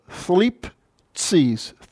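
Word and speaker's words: Thlipsis (θλῖψις): For pronunciation purposes, the psi is cut in two as far as your syllables go. Thlipsis.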